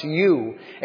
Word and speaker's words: eu: And the beginning of godliness is the letters EU. It just is pronounced U.